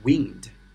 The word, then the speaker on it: wind: W I N D 啊，那么它当名词的时候呢，它是读作 wind 啊，风。那么这里的话呢，大家有没有发现，我把它的读音发生了改变？它读作是 wind，wind，它的意思呢就是蜿蜒啊。